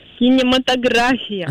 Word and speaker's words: кинематография: В данном слове ударение всегда ставится на слог с последней буквы А, поэтому правильно говорите еще раз, как? – Кинематография.